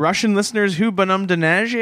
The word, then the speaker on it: Neige: Neige.